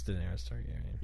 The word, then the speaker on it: Daenerys Targaryen: Daenerys Targaryen. Did I say that right? I've never watched that show. I've only heard it written. I've only seen it written. – Yeah, it's Daenerys Targaryen.